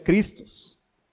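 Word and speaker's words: Christos (Χριστός): El término equivalente en griego es, tampoco se lo voy a decir porque no hablo griego, pero se pronuncia Christos, que significa ungido.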